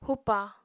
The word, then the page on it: hupa: apatani